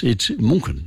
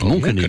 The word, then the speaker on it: München: When they say, oh, I want to pronounce it like the locals, and I say, well, how are you going to pronounce Munich in future? – München, isn't it? – It's München. Yes, it's München in Germany.